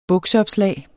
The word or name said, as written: bukseopslag